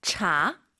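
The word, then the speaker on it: 茶: 이게 분명한 건 한글에 있는 그 차, 한국어의 차는 아니에요. 발음이 차, 차 이거 아니에요. 약간 차, 끈덕지게 차, 그죠? – 是的. – 너무 또 우리가 오버해서 촤, 촤 이건 아니에요. 차.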